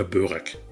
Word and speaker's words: Börek: Sein Unternehmen Itikal Helal beliefert seit vielen Jahren inzwischen mehr als 4600 Lebensmittelgeschäfte in Europa mit Tiefkühlprodukten, wie beispielsweise Köfte oder Börek. Ich hoffe, das habe ich jetzt richtig ausgesprochen, Köfte und Börek, Herr Erkurt. – Ja, das haben Sie.